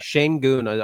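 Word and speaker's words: Şengün: Sengun, I believe, is the pronunciation of the last name.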